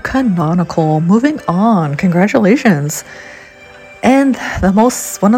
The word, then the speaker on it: canonical: It's pronounced as canonical. Say with me, canonical. It is spelled C-A-N-O-N-I-C-A-L.